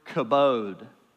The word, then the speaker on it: kabod: That Hebrew word is the word kabod. K-A-B-O-W-D, roughly, right?